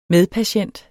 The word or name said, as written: medpatient